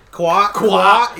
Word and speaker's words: Kawhi: Kawhi.